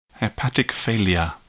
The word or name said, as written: hepatic failure